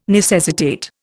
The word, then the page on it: necessitate: Indian